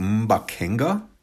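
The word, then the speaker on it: mbaqanga: It's pronounced Mbakenga, but the K is formed with like a popping of the tongue off the top. Do not laugh. But it's like Mbakenga, which is not, I can't do it.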